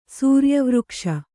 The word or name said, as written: ಸೂರ್ಯವೃಕ್ಷ